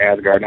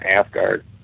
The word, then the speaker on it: Asgard: I do pronounce it Asgard, not Asgard.